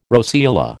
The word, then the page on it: roseola: American English